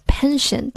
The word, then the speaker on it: penchant: 要注意它的发音，虽然听起来很像耐心 patient 发音，但其实是不一样的。它的开头是 pen penchant，而耐心是 patient pay patient。